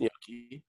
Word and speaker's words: gnocchi: It's pronounced gnocchi.